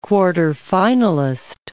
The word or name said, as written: quarterfinalist